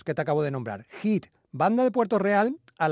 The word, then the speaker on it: HIIT: Se escribe H-I-I-T, Hit.